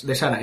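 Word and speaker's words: Serah: Bueno, se escribe Sara, pero es Sara.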